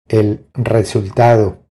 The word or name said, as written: El resultado